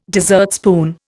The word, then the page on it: dessertspoon: Indian